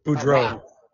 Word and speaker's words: Boudreaux: Also making their AEW television debut, Parker Bondet. – Boudreaux. – Yeah, I can never pronounce that guy's name.